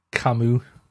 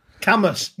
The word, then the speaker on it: Camus: It's Albert Camus. – Camus. You called him but Camus. – But it's spelled C-A-M-U-S.